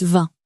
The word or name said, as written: vingt